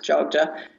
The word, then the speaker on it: Jogja: Borobudur is fairly close to Jogjakarta, so you probably want to base yourself in, it's also called Jogja, but strangely enough spelled Y-O-G-Y, but pronounced Jogja.